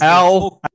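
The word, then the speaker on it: Aulë: and Aye is the, am I saying that right? – I think it's Aule. – Aule. – No, you got me saying it wrong. – This is Aule.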